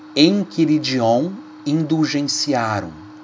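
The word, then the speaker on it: Enchiridion Indulgentiarum: É em latim, então se escreve indulgenti com T, tiarum doctrina E o decreto Enquiridion Indulgenciarum. Enquiridion se escreve enchi com CH, enchiridion, indulgentiarum, mas em latim se lê enquiridion indulgenciarum, da penitenciaria apostólica.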